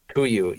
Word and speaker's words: Kuiu: and then Kuiu came out with is that Kuiu? How do you pronounce that? – Kuiu.